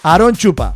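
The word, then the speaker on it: AronChupa: Es genial como Aaron Shupa, lo hemos pronunciado bien, Aaron Shupa. – Sí, pero te falta. – No vamos a hacer chistes malos con este nombre del DJ. – Te ha dejado el. Pronúncialo ahora como Dios manda. – ¿Quieres que lo diga? – Sí. – Aaron Shupa. – Ay, muy bien.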